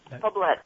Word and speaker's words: Poblet: I'm sorry I'm so challenged when it comes to pronunciation of people's names, but Maria Poblet, right? – Poblet. It's not French. – Poblet, boy, French. Poblet. Okay, there we go.